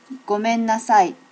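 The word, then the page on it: gomenasai: native Japanese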